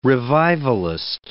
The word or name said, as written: revivalist